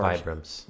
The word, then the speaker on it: Vibram: Now, you are a huge fan of the, am I saying this right? The five-fingered shoes, the toe shoes. – Oh, yeah. – You are Vibrams. – The Vibrams? – Vibram five fingers, yes.